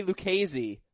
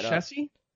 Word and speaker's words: Lucchesi: Are you interested in adding Joey Lucchese? I hope that's how you pronounce it. I forgot to look that Lucchese? – L-U-C-C-H-E-S-I.